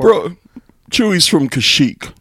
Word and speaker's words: Kashyyyk: Yeah, Kashyyyk. – Kashyyyk, yeah. So I well, a freak. – It's like Kashyyyk rhymes with a freak.